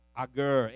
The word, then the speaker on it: Agur: Solomon wrote most of the Proverbs, but here in chapter 30, if I'm pronouncing his name correctly, it's Agur, A-G-U-R, is the one that shows us we need to look at these little creatures.